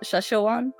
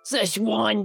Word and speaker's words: Szechuan: Did I say that right, Szechuan? – Yeah. Szechuan, the Szechuan sauce, Morty.